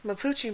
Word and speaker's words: Mapuche: Another type of mythology that comes from the same area is called Mapuche. Did I say that right? – Yeah. – Okay. Mapuche